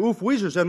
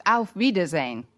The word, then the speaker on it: Auf Wiedersehen: Actually, the word you have in mind is pronounced Auf Wiedersehen.